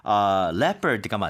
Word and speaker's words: leopard: 아, 참고로 아까 제가 그거를 멘션한다는 게 그 표범 얘기를 할 때 L-E-O라고 시작을 하니까 많은 분들이 레오파르드라고 발음하시는 분들이 있더라고요. 근데 그게 leopard가 맞죠. – Yeah. – 사실 발음 자체가 leopard.